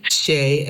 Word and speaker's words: Shay: Shea, S-H-A-Y, which is a French Creole name from Louisiana.